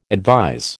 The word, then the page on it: advise: American English